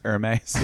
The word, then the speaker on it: Hermes: It's pronounced Hermes. – Yeah. It's Hermes.